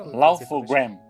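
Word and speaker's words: Laugh-O-Gram: E depois ele abriu a empresa, aquela Laut on, Grains, é assim que se pronuncia? É Lawful Gram.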